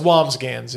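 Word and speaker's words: Wambsgans: Oh, so the S is silent. – Or Wongans. It's Wongans, yeah.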